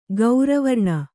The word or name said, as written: ಗೌರವರ್ಣ